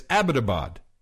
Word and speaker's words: Abbottabad: We were corrected profusely by Pakistanis that it's Abbottabad.